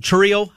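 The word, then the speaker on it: Chourio: I mean, what the Brewers did with, what was it, Churio, I think is how you say the kid's name. – It is. You're right. Yep.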